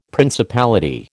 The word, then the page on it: principality: American English